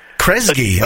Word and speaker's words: Kresge: And let me get his name right. Is it Kresge? Krage? – S. Kresge. – Kresge. Okay, it looks like Kresge, doesn't it?